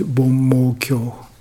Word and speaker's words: Bommokyo: And Japanese pronunciation is Bommokyo, the Sutra of Indra's Net.